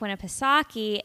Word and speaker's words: Winnipesaukee: but Lake Winnipesaukee is also very familiar.